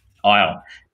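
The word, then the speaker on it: Ehle: We have significant time spent with his family, his wife, played by Jennifer Ely. I'm not sure if I'm pronouncing that right. – Jennifer Isle.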